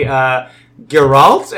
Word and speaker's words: Geralt: So anyway, Henry Cavill is going to get to be Geralt, am I saying that right? – Yeah.